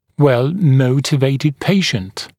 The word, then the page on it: well motivated patient: wel ‘məutɪveɪtɪd ‘peɪʃnt; уэл ‘моутивэйтид ‘пэйшнт